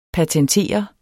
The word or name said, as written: patentere